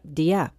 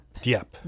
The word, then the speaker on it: Dieppe: This is a discussion called Chasing History, Mysteries of the Dieppe Raid of 1942. And Steve and I had a discussion about how to pronounce it, and we looked it up, Dieppe. – Dieppe. – Dieppe, right. At 2 p.m.